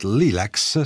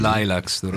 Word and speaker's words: Lilacs: Ok, in questo disco che ho tra le mani, che porta il titolo di Midnight Lilacs, si pronuncia così. – Lilacs, ecco, lo sapevo.